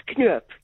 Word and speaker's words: Knoop: It's Knoop, K-N-O-O-P, Knoop.